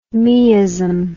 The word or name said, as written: meism